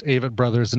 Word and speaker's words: Avett Brothers: And thank you for confirming that they are pronounced Avett Brothers,